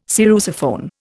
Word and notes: sarrusophone: Indian

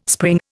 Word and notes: spring: Indian

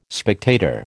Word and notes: spectator: American English

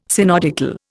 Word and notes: synodical: Indian

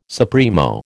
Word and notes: supremo: American English

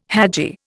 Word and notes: hadji: Indian

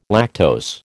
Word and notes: lactose: American English

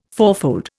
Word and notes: fourfold: Indian

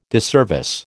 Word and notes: disservice: American English

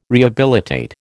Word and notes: rehabilitate: American English

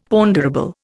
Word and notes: ponderable: Indian

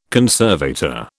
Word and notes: conservator: British English

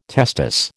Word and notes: testis: American English

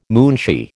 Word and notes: munshi: American English

